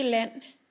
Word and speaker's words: land: Unikke land. Det unikke land. Pas på din udtale af land i forskellige former. Der er ikke, vi udtaler ikke D i land. Det er stumt.